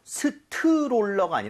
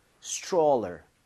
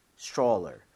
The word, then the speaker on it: stroller: 읽을 때 여기 tr mom 이렇게 되면 얘가 t하고 r을 충돌을 하면서 치읓 비슷해집니다. 그래서 스트롤러가 아니라 입모양을 좀 모으면서 stroller 이렇게 해서 발음을 해보는 거예요. 두번 갑니다. stroller, stroller.